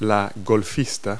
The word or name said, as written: golfer